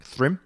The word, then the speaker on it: Thrym: Oh my god, that's what that letter's pronounced like. Thrym.